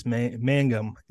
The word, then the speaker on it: Mangum: And then we have Lucas Mangum.